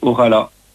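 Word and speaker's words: Ojalá: c'est « Orala ». Ça s'écrit « O-J-A-L-A » et c'est sur le projet.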